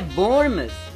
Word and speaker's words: Bournemouth: Bornimal? É, mas a pronúncia é Bournemouth.